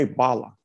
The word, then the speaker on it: bala: Was it, how do I say that last word? – Just say bala. – Bala? – B-A-L-A. Yeah, that's it.